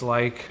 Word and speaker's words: Laich: Yeah, Instagram, across all socials, at Brooks Laich. – Okay, but spell Laich, some of them. – My last name is pronounced like L-I-K-E, but spelled L-A-I-C-H. So Brooks, L-A-I-C-H.